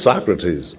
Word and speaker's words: Socrates: I said, Michael, I think before it becomes a habit, I think you should know that the man's name is pronounced Socrates, not Socrates. And he opened his eyes, and innocent as he could be, he said, really? I said, really, yeah. It's Socrates.